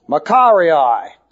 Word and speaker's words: makarioi: A-S-H-E-R-E, long E. Asherei. That's happinesses, literally. Makarii. M-A-K-A-R-I-O-I.